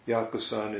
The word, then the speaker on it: Yakusan: So Yaoshan or Yakusan, that's the same name, same person in either in Chinese, Yaoshan, or Yakusan in the Japanese way of pronouncing it.